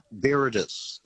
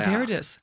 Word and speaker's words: viridis: Yeah, Angiosanthus viridis. – Okay. Now, hang on. Let's test Belinda. Any idea what that was? – Angiosanthus, what was it, flavidus? – Viridis, V-I-R. – Viridis.